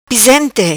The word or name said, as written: Pisente